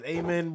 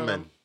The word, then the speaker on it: Amen: I know it's A-M-E-N. I don't know if it's Amen Brown. – Amen. – It's Amen. Amen Brown.